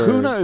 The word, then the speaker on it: Cunha: You know, is it Cunha? – Cunha, I believe. – So like Cunha or? – Cunha is probably the correct pronunciation. – Cunha sounds right.